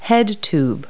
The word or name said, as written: head tube